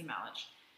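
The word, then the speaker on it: Melich: So after dropping Casey off, Detective Yuri Malich, I believe is how you pronounce it. It's kind of spelled like Malik, but I've heard Malich, so I'm going to say Malich.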